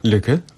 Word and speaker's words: lykke: I druga książka, no ale to już nie wiem, Lykke? – Lykke, yeah.